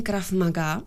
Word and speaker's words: Krav Maga: A učíte je to skrze to bojové umění Krav Maga, jestli to vyslovuju správně. – Vyslovujete to správně, ale není to tak úplně bojové umění, jako spíš systém reálné sebeobrany.